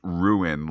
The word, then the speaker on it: Ruhn: It's Primarch Ruhn, R-U-H-N, pronounced ruin, like, you know, can spoil everything. You see, Pete, I think they did an alien spelling of the word R-U-I-N to show how he's just willing to ruin the whole place to get what he wants because he's a bad guy.